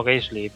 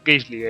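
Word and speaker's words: Gaselee: El 8 de agosto, el embajador británico MacDonald recibe un mensaje de Gassell. Bueno, tú me corriges, ¿eh? ¿Gassell o cómo se dice? – Yo le llamo Gaisley, pero... – Gaisley, pues Gaisley es para mí ya a partir de ahora.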